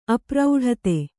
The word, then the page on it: ಅಪ್ರೌಢತೆ: apraudhate; Kannada